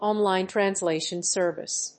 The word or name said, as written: online translation service